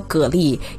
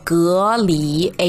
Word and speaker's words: 蛤蜊: 那真正的读音呢，我确认又确认了，叫做隔离。诶，没错，就是那个隔离的隔离，哈哈。